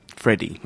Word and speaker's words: Freddie: But also because my name is spelt with an IE, Freddie, IE, like Freddie Mercury, not with a Y, like Freddy Krueger.